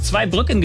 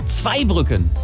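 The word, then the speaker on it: Zweibrücken: Wie zum Beispiel bei zwei Brücken. Wird oft falsch zwei Brücken genannt, heißt aber zwei Brücken. Betonung auf zwei.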